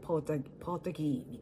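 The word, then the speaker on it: Portagee: なのでその R を発音せずにポートギーみたいな風に言います。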